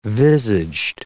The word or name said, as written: visaged